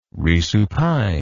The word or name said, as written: resupine